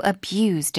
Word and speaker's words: abuse: Animals separated from their owners are also abused in many ways. – 자, abuse 하면 학대하다. Abuse 하면 학대란 명사죠. 동사의 발음이 다르죠.